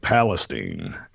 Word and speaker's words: Palestine: I live on Lake Palestine is how we pronounce it.